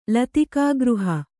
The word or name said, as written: ಲತಿಕಾಗೃಹ